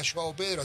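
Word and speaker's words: João Pedro: Joao Pedro, así se pronuncia.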